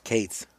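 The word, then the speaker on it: Phoebe Cates: I think I'm going to pronounce, I hope I pronounce her name right, Phoebe Katz. – Kates. – Okay, there you go.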